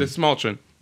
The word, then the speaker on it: Dastmalchian: Dastmalchian. Dastmalchian.